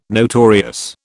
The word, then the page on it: notorious: British English